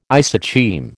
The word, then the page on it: isocheim: American English